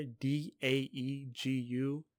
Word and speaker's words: Daegu: Uh, something I read was that it was formerly called Taegu. Or it was it was written Taegu, but it's still pronounced Daegu. But it was T A G T E T A E G U, but now it's D A E G U.